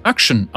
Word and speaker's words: Akshan: Akshan wird Action ausgesprochen. Der erste Vokal wird im amerikanischen Englisch nur selten benutzt, ist in den restlichen Teilen der Welt jedoch weit verbreitet.